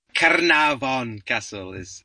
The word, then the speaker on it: Caernarfon: Caernarfon Castle. He put some effort into the Welsh pronunciation, but Caernarfon, yeah.